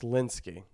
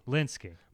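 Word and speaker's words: Lynskey: And the cast looks pretty dope, including Melina Lewinsky. She played the wife plays the wife in Togetherness. – Like Melanie Lewinsky? How did you pronounce it? – Lewinsky. Is that wrong? – No, it's Linsky. – Linsky.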